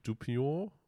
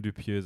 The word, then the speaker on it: Dupieux: Von Quentin, Quentin Dupion oder ich weiß nicht, wie der ausgesprochen ich wird. Ich würde Quentin Dupieux sagen.